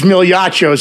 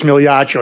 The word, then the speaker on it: Migliaccio: You know, my last name's Migliaccio, so you can imagine TK is backstage. You can imagine how bad that normally goes, Migliaccio. It's like Mr. Muchacho, Milichacho.